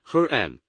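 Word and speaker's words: herem: 现在 harem 它的发音是 harem，这是一个硬 h。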